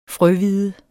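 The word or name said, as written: FRØHVIDE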